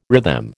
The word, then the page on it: rhythm: American English